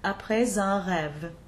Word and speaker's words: Après un rêve: This rule also explains the liaison in the title of the piece, Après un rêve.